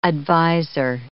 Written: adviser

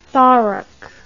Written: thorac

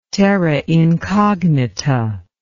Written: terra incognita

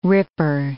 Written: ripper

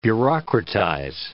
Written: bureaucratize

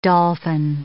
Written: dolphin

